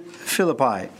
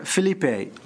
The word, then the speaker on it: Philippi: Philippi, or Philippi, as Mr. Johnson likes to pronounce it, the other Mr. Johnson.